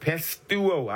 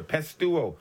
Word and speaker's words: pisteuo: Now, this word believe is the Greek word pistuo. I'll spell it for you. Pistuo. P-I-S-T-E-U-O. Pistuo. P-I-S-T-E-U-O.